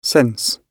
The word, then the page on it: sense: British English